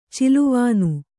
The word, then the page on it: ಚೆಲುವಾನ್: celuvān